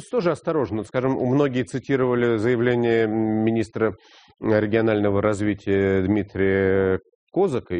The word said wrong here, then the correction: Козака: или как он сам просит ставить ударение в его фамилии, Дмитрия Козака. Так вот, он вроде и сказал, что там...